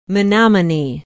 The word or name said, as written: menominee